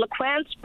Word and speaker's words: LeClance Brook: What do the locals, what do the locals call it? Lecontra? – It's called LeClance Brook. LeClance Brook, okay. Because sometimes, you know, local pronunciation is what we should be using because it's not written that way, but that's neither here nor there.